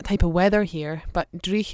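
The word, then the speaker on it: dreich: someone spelled it as that, which kind of made me sad that I wasn't pronouncing it well enough. But Drich. – Drich. – Yeah. So okay. It's D-R-E-I-C-H.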